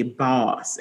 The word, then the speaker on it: Bawse: But the book that she read called How to Be a Boss, and I pronounce it that way because boss is spelled B-A-W-S-E, and it is a guide to conquering life.